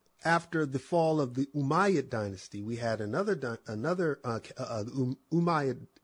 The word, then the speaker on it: Umayyad: called the Umayyad Caliphate. It's spelled U-M-A-Y-Y-A-D, Umayyad Caliphate.